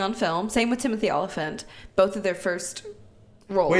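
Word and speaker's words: Olyphant: We've got Timothy Oliphant. How do you pronounce his last name? – Oliphant. – Oliphant. Like elephant.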